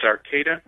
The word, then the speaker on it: Arcata: It's Arcata. It's in California on the coast, northern coast.